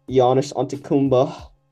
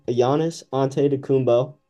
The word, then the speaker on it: Giannis Antetokounmpo: And then for the East, I went with, the Greek feat, Giannis Antetokounmpo. – The worst pronunciation I've ever heard. – I'll say it for real. Giannis Antetokounmpo. – There you go.